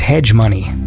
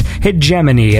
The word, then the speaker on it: hegemony: When I first read that word, I thought it was hedge money. But a little Google and internet sleuthing told me that, in fact, it is pronounced hegemony, and that's an interesting word, H-E-G-E-M-O-N-Y, and if you look it up, you'll learn something, because it means something.